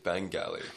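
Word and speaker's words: Bangalley: It's a hard G. Bengali.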